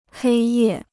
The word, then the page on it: 黑夜: hēi yè